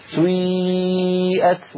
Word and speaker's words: سيئت: كلمة سيئات أصلها سيئات على وزن فعل، لأن هذه الأفعال سيئة وأخواتها أفعال مبنية للمجهول، فبعض القبائل العربية كانوا ينطقونها يبدأون بضمة ثم يعودون إلى الخلف فينطقون ياء هكذا سيئات. هذه الحركة يسميها العلماء حركة مركبة، مركبة يعني من حركتين أولها ضمة وآخرها كسرة كما نطقتها لك سيئت وجوه الذين كفروا، هكذا تنطق يا أخي الكريم.